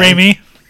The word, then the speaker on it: Raimi: anyway, so Dafoe, who used to play the Green Lantern in Sam Raimi's Spider-Man. – Raimi.